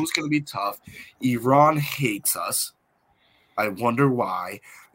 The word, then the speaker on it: Iran: It's pronounced Iran.